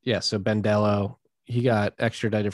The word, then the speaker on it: Dello: I don't think CZ has the same character or metal that Arthur Hayes and crew, Sam Reed and Ben, how do you say his last name? – Dello? – Dello, yes, Ben Dello.